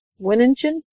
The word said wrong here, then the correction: Swearengen: Swearengen. – Swearengen, okay. – Like swearing. – Right. – And gin. – And gin, yeah.